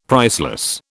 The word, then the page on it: priceless: British English